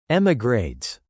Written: emigrates

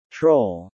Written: troll

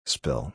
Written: spill

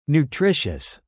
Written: nutritious

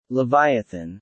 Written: leviathan

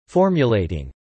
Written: formulating

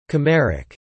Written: chimeric

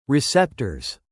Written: receptors

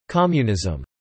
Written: Communism

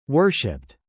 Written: worshipped

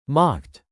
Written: mocked

